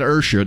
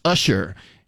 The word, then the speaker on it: Usher: That's how you really pronounce his name. It's not Usher. They're playing when they say Usher. It's Usher. U-S-H-E-R. See, there's only one word, one R in that word, and it's at the end.